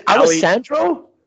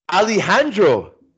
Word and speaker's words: Alejandro: Is it Alessandro? – Alejandro Apron. – Alejandro. – Alejandro.